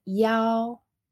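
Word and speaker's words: yao (幺): Y hay un número que es i, que es 1, y no decimos i cuando estamos diciendo un número de teléfono, sino que se pronuncia yao, yao, por ejemplo, yao, yao, san, así, 1, 1, 23, 2, 3, así. No, no se puede decir y.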